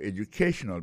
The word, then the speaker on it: educational: We'll be talking about your educational background. Alors, accent tonique, là, quand on peut se publier, là, il tombe sur troisième syllabe là.